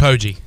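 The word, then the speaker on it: Poggi: Pogey? How do you pronounce it? – Pogey. – Pogey, okay. Earlier,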